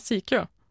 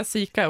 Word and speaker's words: Sikeå: Ja, Sikeå. – Oj, Sikeå. Ja, nu uttalar. Du kan säga Sika också.